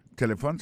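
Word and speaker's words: telephone: Téléphone, c'est comme ça on dit téléphone en anglais. On va répéter encore pour nous. Téléphone. On a remarqué que l'accent tonique l'a tombé sous première syllabe là.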